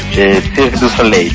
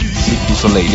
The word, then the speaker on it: Cirque du Soleil: ¿Cómo se dice Circo del Sol? Yo nunca lo pude pronunciar en francés. – Cirque du Soleil. – Cirque du Soleil, ya.